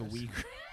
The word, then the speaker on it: Uyghurs: It's where they have their death camps today for the Uyghurs. – The Uyghurs. – Am I saying it right? – No, yeah, that's correct.